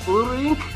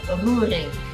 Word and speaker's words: Roerink: Is it Roerink? Is that not bad. I'm impressed. So yes, in Holland, we roll our R's and the O-E is an O sound. So Roerink, not bad.